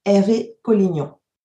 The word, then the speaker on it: Hervé Collignon: Aujourd'hui, on reçoit Hervé Collignon.